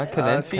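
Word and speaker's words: Calanthe: Kalante? Kalanzi? Ich ja, Calanthi, äh, Calanthi glaube ich, wird sie ausgesprochen.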